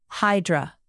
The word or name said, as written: hydra